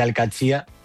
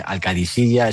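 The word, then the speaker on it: Al-Qadsiah: Bueno, se suele decir Al-Qadsia, pero también hay gente que lo dice Al-Qadisiya. Es que es difícil, ¿eh? Ah, no es fácil, pero Al-Qadsia es lo más fácil.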